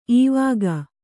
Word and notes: ಇವಾಗ: ivāga

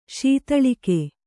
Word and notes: ಶೀತಳಿಕೆ: śitaḷike

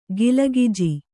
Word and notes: ಕಾಲಗೆಜ್ಜೆ: kālagejje

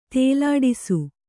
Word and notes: ತೇಲಾಡಿಸು: Kannada

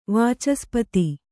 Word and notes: ವಾಚಸ್ಪತಿ: vācaspati

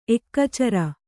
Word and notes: ಏಕಚರ: ēkacara; Kannada

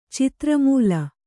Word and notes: ಚಿತ್ರಮೂಲ: citra mūla

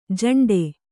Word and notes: ಝಿಂಟಿ: jhiṇṭi

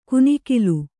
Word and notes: ಕುನಿಕಿಲು: kunikilu; Kannada

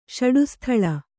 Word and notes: ಷಟ್ಸ್ಥಲ: ṣaṭsthala

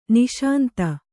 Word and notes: ನಿಶಾಂತ: niśanta

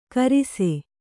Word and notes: ಗಿರಿಸ: Kannada

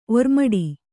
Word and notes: ಓರೆಮಾಡು: ōremāḍu

